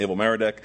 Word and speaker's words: Evil Merodach: A son by the name of Evil Merodach, not evil in the sense that we think evil, but evil is just the way it's pronounced, Evil Merodach.